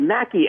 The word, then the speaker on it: Machi: My last name is spelled Mackey, M-A-C-H-I, and it's comedian Joe Mackey on two of the three, and just Joe Mackey on Twitter.